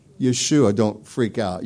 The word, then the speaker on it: Yeshua: How do you say Joshua if you're in Israel? Yeshua.